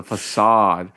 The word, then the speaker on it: facade: facade uh, as a series of mysterious killings spread throughout the ship. – Facade. So, facade. oh, of course, that's how you pronounce it. It's a facade.